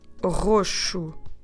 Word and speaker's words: roxo: Another color I want to teach you today for purple, we say roxo roxo.